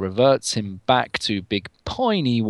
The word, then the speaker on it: Piney: His US birthplace was Big Piney, Wyoming. – Big Peeny, I want to call it, for obvious reasons. – Piney, peeny, butchered. – I think it's piney, but you're right.